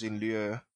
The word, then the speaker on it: in lieu: Sometimes in lieu, that is how you pronounce it, because that's French.